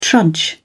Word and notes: trudge: British English

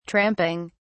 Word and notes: tramping: British English

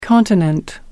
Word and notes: continent: American English

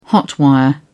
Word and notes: hot-wire: British English

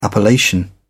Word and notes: appellation: British English

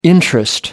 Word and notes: interest: American English

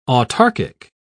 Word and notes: autarkic: British English

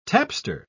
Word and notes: tapster: British English

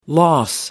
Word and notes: loss: American English

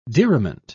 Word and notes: diriment: British English

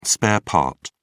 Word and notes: spare part: American English

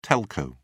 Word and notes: telco: British English

